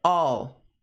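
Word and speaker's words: I'll: Esse all, pronúncia é all, all, all.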